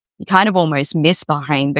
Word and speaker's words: Bahrain: Did I say that correctly? – Bahrain.